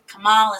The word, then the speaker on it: Kamala: Kamala, Steve. You better say her name right.